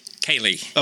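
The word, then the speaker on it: Ceili: I want to say this right because in case people want to reach out to hire you, it's the Indianapolis Chelly? – Kaylee. – Okay. – Oh, Kaylee, like Kaylee. Like Kaylee music.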